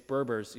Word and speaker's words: Berbers: And then I would tell them about the Barbers, and they'd say it's Berbers, you know, correct my pronunciation.